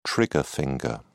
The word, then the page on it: trigger finger: British English